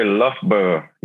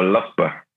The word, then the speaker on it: Loughborough: Yeah, so it's actually Loughborough University. But it's a bit of a tongue twister, like most people kind of, it's a weird one to pronounce. – Okay. – But yeah. – Loughborough.